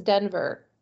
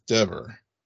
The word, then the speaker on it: Dever: Like, I always hear it pronounced as Denver. – Yeah. I've heard it pronounced Denver, 2 weeks. Denver? Or is it Dever? – I think it's Dever.